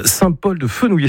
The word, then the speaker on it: Saint-Paul-de-Fenouillet: Saint-Paul-de-Fenouillet, c'est comme ça que vous le prononcez.